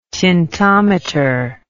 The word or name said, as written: tintometer